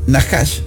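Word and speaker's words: Nahash (נחש): La palabra hebrea, Nahash, así como te lo estoy pronunciando, significa serpiente.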